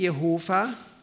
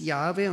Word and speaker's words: Yahweh: Und dann kommt das Wort Yahweh, man kann es auch nennen Yahweh, entweder wird das H gehaucht oder nicht gehaucht, Yahweh oder Yahweh, bitte nicht Jehova. Jehova haben die Zeugen Jehovas gesagt, aber das ist nicht richtig. Da haben sie einen Fehler begangen. Die Aussprache ist Yahweh und kommt von dem Wort Ich bin, der ich bin.